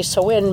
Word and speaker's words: Samhain: And it's spelled Samhain. So when I say Samhain, maybe people aren't going to connect it with the word that they've read.